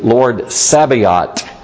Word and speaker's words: Sabaoth: Lord Sabaoth, or more correctly pronounced in Hebrew, Lord Sabaoth with a hard T. Lord Sabaoth. And the Lord of hosts, the Lord of armies.